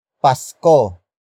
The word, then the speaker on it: Pasko: Pasko. Pasko. Pasko is spelled as capital P-A-S-K-O.